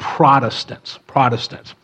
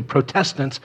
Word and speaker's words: Protestants: And it probably would be better if we called ourselves the Protestants, not the Protestants, because when you say Protestants, it's clear. When we say Protestants, I feel that we put the emphasis on the wrong syllable.